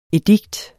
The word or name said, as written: EDIKTERNES